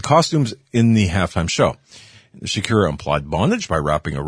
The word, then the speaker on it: Shakira: It was therefore appropriate for the halftime show to have a Latin beat with Shakira, I guess, what, Shakira, that's how you pronounce it, and J-Lo as headliners.